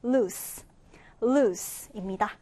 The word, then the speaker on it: loose: 자, 이 단어 발음 주의해 주세요. 우리 S 발음으로 loose, loose입니다.